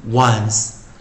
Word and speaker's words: once: 所以在这个循环中，在这个循环中啊，老师再来再念一遍 O N C once once once。那注意，它是 close syllable 关闭音节，short vowel 短元音，并且还是 variant 破音形态啊，破音形态啊。